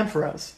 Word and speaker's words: Ampharos: Ampharos. – Yeah, Ampharos.